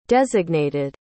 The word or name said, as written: designated